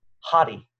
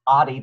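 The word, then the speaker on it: hadi (ὅτι): It's pronounced hadi, okay? It looks like adi, but this little backwards comma right here gives it the H sound, so it's actually pronounced hadi,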